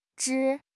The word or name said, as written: zhi1